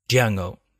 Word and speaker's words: Django: että Django, miten se sanookaan niin kuin Django, että se D ei kuule, hän sanoo, että niin. The D is silent, niin tämä vastaa tämä Franco Nero sitten vaan, että I know, koska hän on itse esittänyt myös Djangoa vuonna 66.